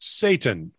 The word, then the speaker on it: seitan: As you know, vegan roasts are made with seitan, pronounced Satan, S-A-Y-T. T-A-N, but it looks like S-E-I-T-A-N. Well, that's how it's spelled. It's just pronounced Satan.